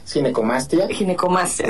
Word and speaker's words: ginecomastia: Para empezar, la palabra, le decía, ¿cómo se pronuncia? ¿Ginecomastia? ¿Ginecomastia? – Sí, exacto. Ginecomastia, exacto.